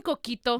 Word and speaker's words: coquito: Do you mean coquito? – Coquito.